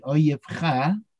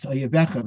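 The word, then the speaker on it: oyivcha (אויביך): When you see the downfall of your enemy, oyivcha, it's often pronounced oyivecha, but that's actually incorrect, and I made that mistake myself a lot of times. B'nafal oyivcha al tismach, do not rejoice upon seeing your enemy's downfall.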